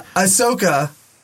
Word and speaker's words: Ahsoka: Ahsoka for Disney Plus. – Ahsoka Tana. – Ahsoka Tana, yeah. Ahsoka Tana. – Because I always think Ahsoka Tana.